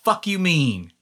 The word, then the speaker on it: Fukumean: who had made this song called Fuck You Mean. And it's spelled... It's spelled F-U-K-U space M-E-A-N. Fuck you mean. Like, the fuck you mean? – Yeah.